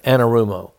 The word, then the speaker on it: Anarumo: It, that may be the same thing with Lou uh, Armano. That that uh, and I'm not saying his name right, uh, Anarumo. That maybe be the same thing for Lou Anarumo.